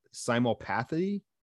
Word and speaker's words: simulpathy: And this is, again, correct me if I mispronounce this, simulpathy. – You got it.